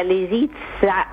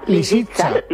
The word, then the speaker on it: Lisitsa: que está interpretada por Valentina Lisitsa, lo he dicho bien. – No, lo has dicho fatal. – A ver cómo es. – Pues hombre, Lisitsa. – ¿Lisitsa? – Lisitsa. Lisitsa, pues, con ese.